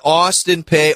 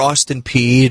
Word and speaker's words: Austin Peay: So they get Austin Peay or Austin Peay, depending on your pronunciation.